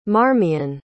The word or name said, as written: marmion